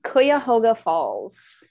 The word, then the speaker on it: Cuyahoga Falls: Cuyahoga Falls.